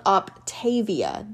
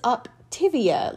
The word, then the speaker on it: Optavia: It bothers me when things are pronounced differently than they're spelled. Phonetically, it should be called Optavia. That is how it is spelled. Instead, everyone refers to it as Optivia, like that weird yogurt.